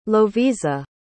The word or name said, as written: lovisa